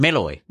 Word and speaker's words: Malloy: It says Alison Malloy. – Malloy. – Malloy. – It's like some sort of metal. – Yes, like alloy.